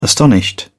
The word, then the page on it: astonished: British English